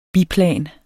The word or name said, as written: biplan